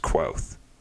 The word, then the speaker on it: Kvothe: So it's set up to be three books, but by the end of the second book, he's not even Kvothe yet, like in the sense that it's the story of how, and I guess it's supposed to be pronounced Quoth.